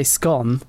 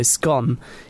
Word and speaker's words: scone: And it actually says that pronunciation that rhymes with gone, so scone, is traditionally more associated with the northern working class.